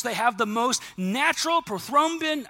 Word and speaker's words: prothrombin: Well, in the 1930s, a bunch of scientists discovered a blood clotting agent to heal wounds, and someone graciously helped me correct the pronunciation of this, which is prothrombin. Nailed it. In the 1930s, discovered prothrombin, which is a vitamin K kind of thing that creates blood clotting so that your wounds can heal closely.